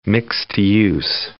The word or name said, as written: mixed-use